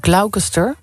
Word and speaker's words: Gloucester: De Engelse plaatsnaam Gloucester spreek je uit als Gloucester. – Gloucester. Ja. Gloucester.